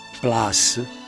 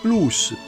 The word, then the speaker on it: plus: È sempre latino e non si legge plus, ma si legge plus perché è un sostantivo neutro di terza declinazione plus pluris che significa di più, un maggior numero, una maggiore quantità.